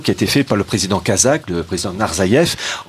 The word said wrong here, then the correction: Nazarbaïev: en 2009. Nazarbaïev pardon.